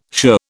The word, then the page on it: shirr: British English